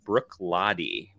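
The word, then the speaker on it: Bruichladdich: It's pronounced Brooklady, which I did not think that was how it was pronounced until I looked it up on Google.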